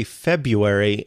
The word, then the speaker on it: February: I typically just say February and ignore that first R,